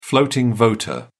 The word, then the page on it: floating voter: British English